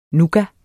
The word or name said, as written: nougat